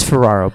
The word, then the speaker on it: Ferraro: It's Ferraro, but I'm such a fucking idiot.